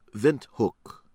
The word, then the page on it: Windhoek: American English